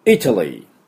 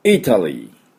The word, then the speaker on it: Italy: どういう発音するかというと、 Italy、 Italy というふうに発音します。 Italy です。この I、 I ですね。 I、喉の奥で I と鳴らします。